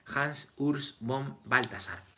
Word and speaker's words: Hans Urs von Balthasar: Es así, los grandes estudiosos, los que estudian, dice Hansus, eh, eh, joder, a ver Pablo, ayúdame, ¿cómo se pronuncia? Hans Urs von Balthasar, que es un teólogo suizo, me parece.